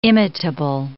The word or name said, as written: imitable